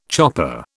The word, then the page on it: chopper: British English